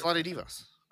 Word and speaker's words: Vlade Divac: It's Vlade Divac. That's how he pronounces it.